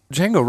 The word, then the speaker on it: Django: Django Reinhardt starts with a D, believe it or not. D-J-A-N-G-O.